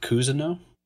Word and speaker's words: Cousineau: It's pronounced Cousineau. – Cousineau. – Or at least that's how I pronounce it. I don't know. It's French.